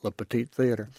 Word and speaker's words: Le Petit Theater: I mean, the Le Petit Theater, as they call it, I spent 2 years trying to get my mouth around the French pronunciation of theater, and then I learned they call it Le Petit Theater.